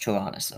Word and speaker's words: Tyrannus: It's pronounced Tyrannus, okay.